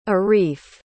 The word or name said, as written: areef